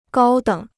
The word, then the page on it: 高等: gāo děng